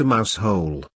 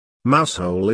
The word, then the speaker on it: Mousehole: Mousehole is a quaint Cornish village whose name the locals pronounce in a very special way that sounds like something between muzzle and mousel.